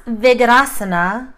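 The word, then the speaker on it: Vyaghrasana: So it's pronounced Vigrasana, which allowed me to sort of roll an R that wasn't there.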